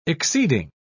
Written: exceeding

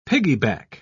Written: piggyback

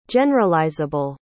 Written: generalizable

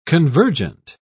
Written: convergent

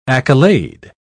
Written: accolade

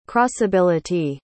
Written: crossability

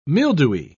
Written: mildewy

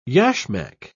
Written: yashmak

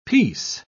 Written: piece

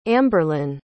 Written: amberlin

